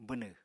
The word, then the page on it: bwnw: apatani